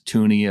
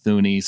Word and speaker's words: Thuney: and I learned how to pronounce Toonie. Uh, apparently, it's not Thuni.